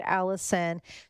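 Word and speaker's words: Alyson: It's A-L-Y-S-O-N, one L and a Y, code Allison.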